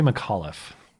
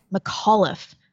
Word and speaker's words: McAuliffe: Then 1 hour later, how do you say the Virginia governor's last name? – Terry McAuliffe. – McAuliffe. – Yeah. – Oh, you think it would be an O-U-G-H, right? McAuliffe?